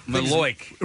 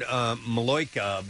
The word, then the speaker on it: Malocchio: Maloic. – Ralph Macchio, right? – I think it's the Maloic. It's the Malachi crunch. – Well, it's spelled M-A-L-O-C-C-H-I-O. I'd heard Maloica before. – Maloica, that's what I've heard. – But this is spelled M-A-L-O-C-C-H-I-O.